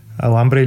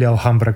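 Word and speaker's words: Alhambra: Аламбра или Алхамбра, както е, mm. ако използваме по-арабското произношение, беше едно от тях.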